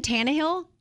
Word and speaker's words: Tannehill: Ryan Tannehill is 32. Did I say that correctly? You did. Okay.